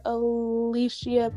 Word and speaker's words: Aleechea: In today's episode, we have the privilege of speaking to Pastor Aleechea Pitts.